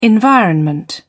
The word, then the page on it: environment: British English